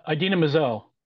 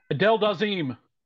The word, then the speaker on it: Idina Menzel: Idina Menzel. – Correct. And you got the name right. – Yeah. – Adele Dazeem.